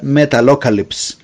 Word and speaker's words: Metalocalypse: Deathlok es una banda básicamente ficticia que empezó con un programa de televisión que se llamaba Metapocalypse o Metalocalypse o Metal, es difícil de pronunciar, en realidad Metalocalypse, que fue creado por Brendan Small.